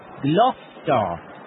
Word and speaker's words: Gloucester: G-L-O-U-C-E-S-T-E-R. – Aha! You pronounced it wrongly. It's Gloucester. That's how you say it.